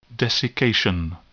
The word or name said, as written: desiccation